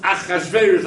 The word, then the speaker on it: Achashverosh: It's written Ach Rash. It's missing both Voven. But it's pronounced Achashverosh like every other time you've read Achashverosh a billion other times.